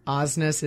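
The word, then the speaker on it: Aasness: I was telling David, our producer, that you were going to come up with your, what is it, Ozness is awesomeness or whatever hashtag. – No, my last Ozness rhymes with awesomeness. That's how I can get people to pronounce it correctly. – Let's not even talk about what mine rhymes with. – N-E-S-S.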